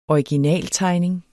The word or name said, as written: originaltegning